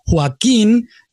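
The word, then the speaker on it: Joaquín: So Joaquin has been stressed on that kin last syllable. If it did not have an accent, it would mean the stress would be on the first syllable, would be Joaquin, which is a different guy.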